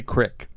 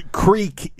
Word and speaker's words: creek: The original dictionary didn't say crick. – Right. – So, the creek is how you say it.